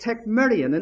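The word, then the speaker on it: tekmerion (τεκμήριον): And the word that he uses is a Greek word pronounced tekmerion.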